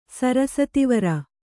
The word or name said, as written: ಸರಸತಿವರ